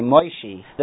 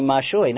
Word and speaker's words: Moshe: Some people like mispronounce it. They say Moishi. The grammatical form should be Mashui, not Moshe the drawer.